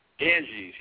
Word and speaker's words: Ganges: Ganges, yes.